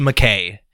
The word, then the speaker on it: Mackay: I believe it's Mackay. It's how it's spelled, M-A-C-K-A-Y. So Mackay makes sense.